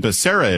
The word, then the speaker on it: Becerra: How do you pronounce the last name? I keep hearing all these different. It's always been Becerra in my book, but is it Baccara? – It's always been Becerra in my book, too.